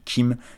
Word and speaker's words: Keem: Donc je vous encourage fortement à aller écouter l'album The Melodic Blue de Baby Kim.